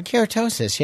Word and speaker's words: keratosis: Keratosis, yeah.